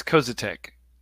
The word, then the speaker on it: Koziatek: The last name is Koziatek.